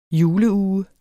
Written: juleuge

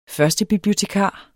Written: førstebibliotekar

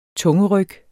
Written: tungeryg

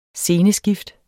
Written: SCENESKIFT

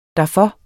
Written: derfor

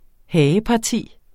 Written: hageparti

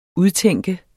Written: udtænke

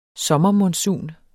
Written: sommermonsun

